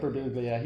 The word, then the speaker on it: Birbiglia: The best, absolute best one that I watched was Mike Birbiglia. Am I saying that right? – Yeah. – Yeah, Mike Birbiglia.